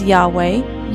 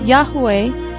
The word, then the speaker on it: Yahweh: And these would be transliterated into English as YHWH or YHVH and would be pronounced as Yahweh, Yahweh, Yahuwah, Yehovah, or something similar.